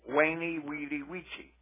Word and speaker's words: veni, vidi, vici: Because the letter U, the Romans had no V in their alphabet, and the letter U, when it's followed by a vowel and starts a consonant, is pronounced like we would pronounce a W. Wah. Waney, weedy, weechy. You see, there's no V in the Latin alphabet. It's a U. The U sound is represented by the V symbol in the Latin alphabet.